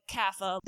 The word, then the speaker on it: Kapha: So Vata, Pitta, and Kapha, which just for the record is spelled like Kapha. – That's right. And if we were to get really specific with the Sanskrit pronunciation, it's Kapha.